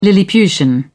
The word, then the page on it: lilliputian: British English